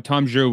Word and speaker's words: Zhu: Yeah, that's very interesting, because during the investor day, when Tom Zhu was did I pronounce that yeah. – right? Oh my god, I think I nailed it.